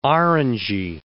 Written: orangey

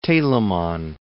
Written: Telemann

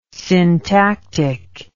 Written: syntactic

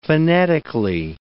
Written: phonetically

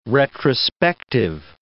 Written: retrospective

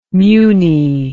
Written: muny

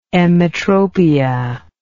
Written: emmetropia

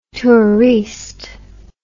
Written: touriste

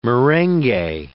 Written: merengue

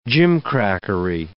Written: gimcrackery

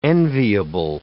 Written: enviable

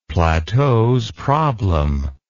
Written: plateausproblem

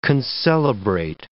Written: concelebrate

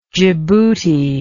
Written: Jibuti

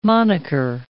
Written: monicker

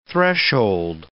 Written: threshold

